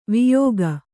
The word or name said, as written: ವಿಯೋಗ